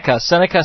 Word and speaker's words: Seneca: Seneca, South Carolina, Fun Radio Online.